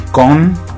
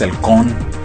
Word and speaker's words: Caen: Ah, por cierto, el Caen se pronuncia con. O sea, perdón por esa pronunciación. Es que, bueno, es un poco difícil dejar la forma latina de pronunciarlo. Pero bueno, así es en francés, el con.